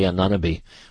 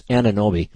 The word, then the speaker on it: Anunoby: I always want to say Anunoby, but it's Anunoby. He threw the ball in, and Jalen Brunson took a shot, and it came off the rim, and Anunoby,